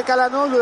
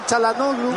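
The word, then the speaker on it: Çalhanoğlu: Por eso le decimos Koku, pero la pronunciación correcta debería ser Kochu. La misma cosa de Calanoglu, decía Chalanoglu.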